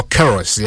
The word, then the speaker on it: Queiroz: Carlos Queiroz or Queiroz, yeah, that's the actual pronunciation. Queiroz.